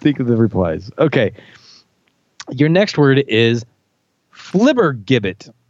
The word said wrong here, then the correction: flibbertigibbet: I left out a syllable. It's flibbertigibbet. Flibbertigibbet.